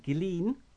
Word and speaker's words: Ghislaine: Because the one thing you can say that Ghislaine, I believe that's the correct way to say her name, the one thing she's done is she's put the Maxwell name well and truly back on the map.